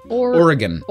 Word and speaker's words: Oregon: Oregon. – Oregon. – It's almost like Oregon. Like we would say Oregon.